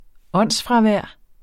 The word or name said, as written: åndsfravær